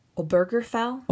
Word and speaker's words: Obergefell: Like we saw in Obergefell. How do you pronounce that? – Okay. Obergefell? – Obergefell. – Or, yeah, that's the best way.